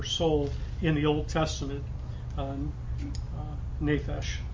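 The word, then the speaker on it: nephesh (נפש): Well, the Hebrew of that word is this word persons here, and it literally means nephesh, or that's how you pronounce the Hebrew word.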